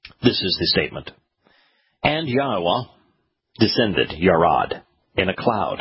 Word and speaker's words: Yahweh: And Yahweh, that is God's name, that is how it is pronounced, Yod-Heh-Wah-Heh, and Yahweh, descended, Yarad, came down, lowered himself, diminished himself.